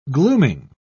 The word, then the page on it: glooming: British English